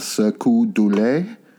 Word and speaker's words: Cirque du Soleil: So in the late 90s, George Harrison met the founder of Cirque du Soleil.